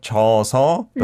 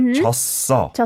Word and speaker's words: 졌어: So not 져서, but 졌어. Mm-hmm. – 졌어. – Kind of continues to both syllables.